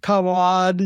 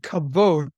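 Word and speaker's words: kavod (כבוד): I'm glad you actually pronounced it right. It's the Hebrew. Often it's pronounced kavod, you know, but it's actually kavod.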